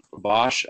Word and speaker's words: Bosch: Bosch, I actually recognized in the last podcast I spelled my last name wrong, and I'm truly embarrassed by it.